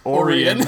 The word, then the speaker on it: Orion: And that's why I have the joke, the recurring joke, which the Orion slave girl, which is apparently pronounced Orion slave girl.